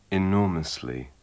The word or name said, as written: enormously